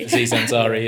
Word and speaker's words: Aziz Ansari: that case with Aziz Azari. I don't know how to say his name properly. – Aziz Ansari, yeah.